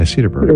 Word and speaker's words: Cedarburg: Cedarsburg, Wisconsin. Cedarburg. Did I say that right? Yeah, Cedarburg.